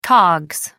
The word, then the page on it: cogs: American English